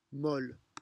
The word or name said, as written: molle